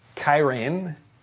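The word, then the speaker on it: kairēn (χαίρειν): And let me tell you how to pronounce the word in Greek, kairēn, because Paul changes it to a sound-alike word, charis.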